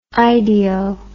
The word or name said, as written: ideo